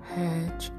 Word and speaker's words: Hajj: Number 4, Psalm, S-A-W-M, Psalm, the fast of Ramadan, Psalm, the fast of Ramadan. And number 5, Hajj, H-A-J-J, Hajj, pilgrimage to Mecca, Hajj, or in this country, America, you'll hear Americans say Hajj, Hajj. Although categorized in different ways, most Shia, S-H-I-A,